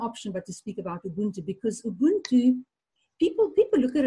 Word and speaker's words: Ubuntu: Yeah, it's uBuntu.